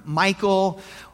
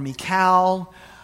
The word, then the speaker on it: Michal: Now, her name, it's been pronounced different ways. It's spelled M-I-C-H-A-L. Some people have called her Michael or Michal or Michal. I'm going to go with Michal if there's no objection.